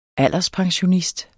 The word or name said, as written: alderspensionist